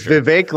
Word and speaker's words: Vivek: It's Vivek like cake. – Okay. You know, I think you have to have a wait and see attitude. I don't think that Vivek is someone that is tested.